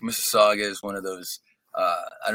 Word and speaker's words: Mississauga: Mississauga? How do you pronounce the name of your? – Oh, that's Mississauga.